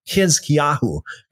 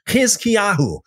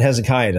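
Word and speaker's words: Hizkiyahu (חזקיהו): By the way, I don't know how many of you would know, but Hezekiah, the name Hezekiah in the Hebrew, the correct pronunciation is Hizkiyahu. Hizkiyahu in, in Hebrew. It uh, sounds a whole lot different than Hezekiah, doesn't it?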